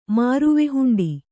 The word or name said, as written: ಮಾರುವೆಹುಂಡಿ